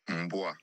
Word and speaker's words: Mboa: Yeah, with a silent M? – Go ahead. – Mboa.